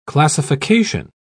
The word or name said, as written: classification